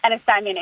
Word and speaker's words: Ennistimon: Well, we were actually able to visit the town of Ennistimon or Ennistimon. I'm not sure if I'm pronouncing that correctly. – You have it right. – Okay, Ennistimon, and we were able to go there, which is really neat.